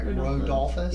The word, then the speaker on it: Rodolphus: Rodolphus.